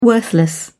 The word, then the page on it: worthless: British English